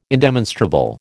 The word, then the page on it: indemonstrable: American English